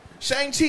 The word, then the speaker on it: Shang-Chi: Chang. Help me out. Disney. Chang Lee. Chang. – No idea. – Ten Rings movie. – Shang-Chi and the Legend of the Ten Rings.